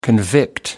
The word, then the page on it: convict: American English